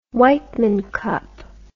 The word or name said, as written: Wightman Cup